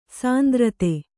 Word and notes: ಸಾಂದ್ರತೆ: kannada

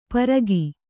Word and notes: ಪರಗಿ: paragi

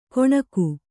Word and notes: ಕೊಣಗು: Kannada

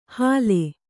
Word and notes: ಹಾಲೆ: hāle

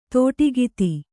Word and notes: ದಾಟುಕಥೆ: Kannada

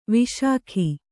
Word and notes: ವಿಶಿಖ: viśikha

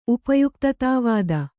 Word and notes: ಉಪಯುಕ್ತತಾವಾದ: upayuktatā vāda

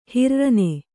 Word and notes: ಹಿರ್ರನೆ: Kannada

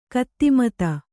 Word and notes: ಕಿದ್ಮತ್ತು: kidmattu

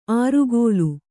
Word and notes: ಆರುಗೋಲು: ārugōlu

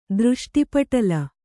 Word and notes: ದೃಷ್ಟಿಪಟಲ: dřṣṭi paṭala; Kannada

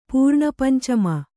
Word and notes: ಪೂರ್ಣಪಂಚಮ: Kannada

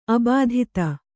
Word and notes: ಅಬಾಧಿತೆ: abādhite; kannada

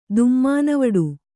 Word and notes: ದುಮ್ಮಾನವಡು: kannada

